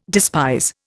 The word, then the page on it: despise: Indian